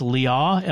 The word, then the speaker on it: Liaw: I think it's Liaw, L-I-A-W, Liaw, something. He's Australian.